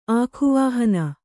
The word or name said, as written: ಆಖುವಾಹನ